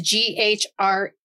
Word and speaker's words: ghrelin: It's GHRELIN. Ghrelin. – Ghrelin. – Ghrelin. I'm grilling.